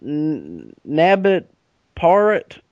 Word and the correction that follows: Nanda Parbat: you know, the Himalayan. – Nanda Parbat? – Nanda Parbat, yeah, the Himalayan stronghold.